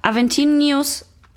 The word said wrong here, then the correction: Aventinus: Ach nee, ich dachte gerade, der heißt Aventinius und ich habe das falsch gesagt. Der heißt Aventinus.